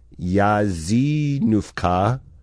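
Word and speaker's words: jeżynówka: It's a word that is spelled J-E-Z-Y-N-D-W-K-A. They say it's pronounced Yazinówka.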